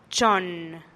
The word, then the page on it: chonn: TdVZ